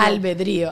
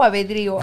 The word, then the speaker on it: albedrío: Albedrío. – ¿Albedrío o abedrío? – Albedrío. – Vamos a Google. – Whiplash, por favor, ponlo albedrío en pantalla.